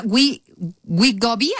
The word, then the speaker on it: Wegovy: hay otro que se llama Wegovy, así se pronuncia. – Wegovy.